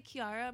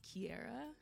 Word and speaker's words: Kiara: Because I say Kiara. My parents say I say it wrong, and it's pronounced Kiara. – But there's no E. – But there's no E exactly. So by my book, it's Kiara, and in Japanese, Kiara.